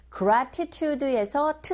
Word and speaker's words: gratitude: 이런 경우 to 사운드 사라져버리고 r 소리에 가깝게 들려서 how to, 더군다나 강세를 받지 않는 to이겠지라 How to pay, 여기 강조해 주시고 gratitude에서 t 사운드가 지금 강모음과 양모음 사이에 껴있는 모양입니다. 이럴 경우 역시 t 사운드, 맞아요. 정말 빈번하게 일어나는 현상이죠. 플랩 현상. gratitude, 강세에 이 부분에 주셔서 발음하시면 됩니다.